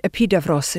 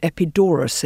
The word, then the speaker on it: Epidaurus: They call it Epidavros in Greek, and here in English, we call it Epidaurus in our country because it's easier than saying Davros, I think.